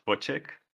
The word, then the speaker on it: Wojcik: I, I, pronounce it as as Wojcik. – Wojcik. – Yeah, but in fact, at at the, uh, you know, it's a Polish Polish surname, and the Polish people would pronounce it slightly different. – Okay. – okay. Even I myself uh, pronounce like in more Dutch ways.